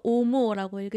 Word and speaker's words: OMO: 오, 그래서 O-M-O 해서 오모라고 읽으시는 거죠? – 네, 맞아요.